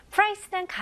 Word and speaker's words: price: Price는 가격입니다. 네, 발음 연습부터 해볼게요. 네, 이거는 price가 아닙니다. 이름절로 price. Price. Okay? 밥 혹은 쌀도 rice라고 하잖아요. 네, 발음이 똑같아요. 거기다가 p만 붙이면 돼요.